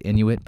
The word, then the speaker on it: Inuit: Amy said Inuit back in Alaska, and I'm saying Inuit here since that's how people in Iqaluit pronounce it.